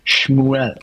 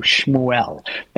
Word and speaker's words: Shmuel: But you know, we say Samuel, but uh, uh, that's not the way he would have heard it because in Hebrew, which his name is Shmuel. That's right. – That's how you say it in Hebrew, Shmuel, and it means that his name is God.